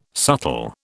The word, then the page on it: subtle: British English